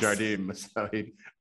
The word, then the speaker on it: Jardim: So Portuguese. You pronounce the J, okay, Dennis. – Jardim.